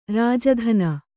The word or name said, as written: ರಾಜಧಾನಿ